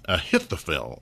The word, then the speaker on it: Ahithophel: And then the third person, Ahithophel. Now, this is spelled A-H-I-T-H-O-P-H-E-L.